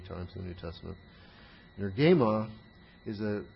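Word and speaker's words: Energeima (ἐνέργημα): We have another noun, energeima, very closely related to energeia. Energeima. E-N-E-R-G, long E-M-A.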